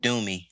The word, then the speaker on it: Doomie: I actually prefer to be called Doomie. Doomie is spelled D-O-O-M-I-E.